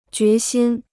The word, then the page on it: 决心: jué xīn